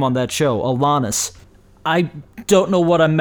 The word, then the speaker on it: Alanis: But I did watch the Alanis Morissette performance, which, by the way, I do believe it's pronounced Alanis. That's how they pronounced it back when she was on You Can't Do That on Television.